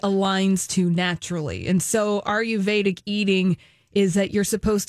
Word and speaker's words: Ayurvedic: A-Y-U-R-V-E-D-I-C. – Ayurvedic.